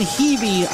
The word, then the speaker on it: Hebe: Landon Hebe on the stop. If you take a look at a roster, it looks like it should be pronounced Heavy, but it is Hebe, and we have seen a few members of the Hebe family play for Western over the past several years.